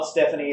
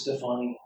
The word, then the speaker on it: Stefani: And it's not Stephanie, it is Stefani.